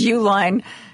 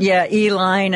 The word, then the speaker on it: Uline: Since I don't know how, I always say, because his company is Uline Packaging, so I always assume their name is Uline. It works for me. I've heard other people pronounce it, Eline and other things.